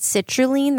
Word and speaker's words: citrulline: There's one known as, I believe you pronounce it citrulline. That's how I would do it as a chemist.